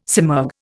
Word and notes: simurg: Indian

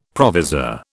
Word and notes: provisor: British English

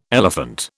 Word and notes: elephant: British English

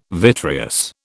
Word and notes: vitreous: British English

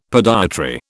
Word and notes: podiatry: British English

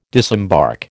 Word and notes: disembark: American English

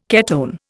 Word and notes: ketone: Indian

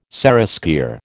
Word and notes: seraskier: American English

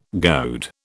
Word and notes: goad: British English

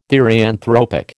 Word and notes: therianthropic: American English

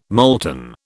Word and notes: molten: British English

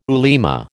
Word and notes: ulema: American English

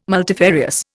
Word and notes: multifarious: Indian

